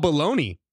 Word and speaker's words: bologna: Baloney is spelled B-O-L-O-G-N-A. Oh, really? A word that is pronounced Bologna? You're telling me I have to name it, I have to pronounce it baloney?